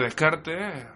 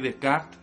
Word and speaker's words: Descartes: partiendo del mismo René Descartes, o René Descartes, si lo queremos decir en francés, nos tenéis que permitir que parte de la pronunciación, cuando nos referimos a otros filósofos, la hagamos, en, un español profundo y castizo.